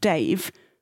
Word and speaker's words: Davy: Well, it's spelled Davy, but apparently it's called Dave.